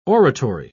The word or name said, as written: oratory